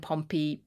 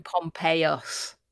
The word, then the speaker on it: Pompeius: So we tend to call him Pompey when we anglicize it, but the Romans would have called him Pompeius with a US at the end.